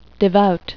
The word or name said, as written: devout